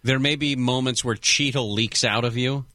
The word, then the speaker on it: Cheetle: Okay, it's not spelled like Don Cheadle, the actor. That's C-H-E-A-D-L-E. This is C-H-E-E-T-L-E. Cheetle.